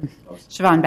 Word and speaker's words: Siobhan: I want to especially thank the Portland Housing Bureau's underwriters, John Marshall, Sohoban Beto. Did I pronounce that right? – Siobhan Beto.